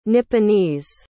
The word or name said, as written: nipponese